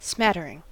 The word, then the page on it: smattering: American English